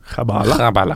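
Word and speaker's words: Qəbələ: Og ting tyder på at det uttales khabale. – Khabala. Khabala.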